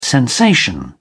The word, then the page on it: sensation: British English